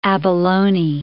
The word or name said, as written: abalone